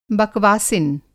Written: बकवासिन

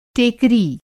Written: टेकरी

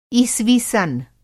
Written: ईसवी-सन्

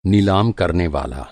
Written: नीलाम

